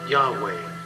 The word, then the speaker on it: Yahweh: But the Hebrew would be pronounced something like Yahweh, expressing the word for I am.